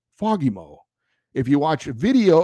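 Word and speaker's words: Fagemo: He texted me back that it's pronounced Fagimo, with the emphasis on the second syllable, Fagimo.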